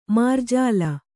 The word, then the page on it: ಮಾರ್ಜಾಲಿ: mārjāli